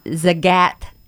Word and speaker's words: Zagat: Zagat Atlanta. – Yeah, say it right. There you go. Zagat. Good girl. – I've been saying Zagat because it looks like Zagat.